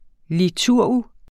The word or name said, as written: liturg